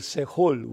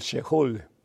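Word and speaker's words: Seol: le llamaban los judíos el Sehol o el Shehol, depende la pronunciación que le quieras dar, que, sea, que quiere decir lugar de los justos.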